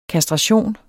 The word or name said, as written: kastration